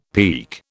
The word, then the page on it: peke: British English